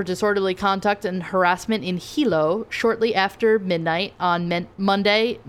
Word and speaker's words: Hilo: A 29-year-old person visiting from Vermont was arrested and charged for disorderly conduct and harassment after an incident at a bar in Hilo. I also think that's how you say it, H-I-L-O.